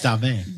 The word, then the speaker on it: Stave: And we almost went to the Thirsty Monk last night to try that beer that they collabed with Crooked Stav. What was it? – It's pronounced Stave.